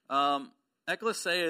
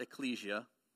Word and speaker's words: ekklesia: It is pronounced ekklesia. It is not ecclesia. John Papp likes to pronounce it ecclesia just to drive me crazy.